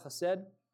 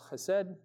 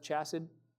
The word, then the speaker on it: Chesed: And then Beth Messiah, for a while we thought it would be Beth Chesed, C-H-E-S. But then my dad said nobody can pronounce Chesed. They would call it Beth Chassid.